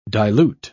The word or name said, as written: dilute